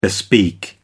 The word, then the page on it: bespeak: American English